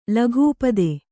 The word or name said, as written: ಲಘೂಪದೆ